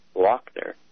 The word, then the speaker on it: Loughner: Jared Loughner, pronounced by the sheriff as Lochner, saying it was a Polish pronunciation.